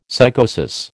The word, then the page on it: psychosis: American English